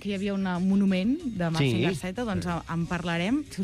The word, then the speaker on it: Mazinger Z: En japonès es pronuncia Mazinger Z.